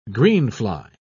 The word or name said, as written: greenfly